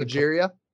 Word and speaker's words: progeria: I think progeria.